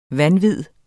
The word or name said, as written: vanvid